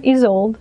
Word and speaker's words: Isolde: Isolde.